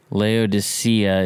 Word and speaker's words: Laodicea: Um, Laodicea is the way that people pronounce it a lot of the time, but I think if you really get into it, the uh, the the Greek word might actually not be Sia but Kia, like with a K kind of a sound.